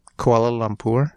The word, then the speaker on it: Kuala Lumpur: Kuala Lumpur.